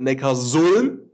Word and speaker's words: Neckarsulm: Oldenburg, die haben 32-26 gewonnen gegen die Sportunion Neckarsulm. Ich habe es beim ersten Mal direkt gut ausgesprochen.